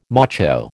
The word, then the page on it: macho: American English